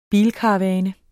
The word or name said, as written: bilkaravane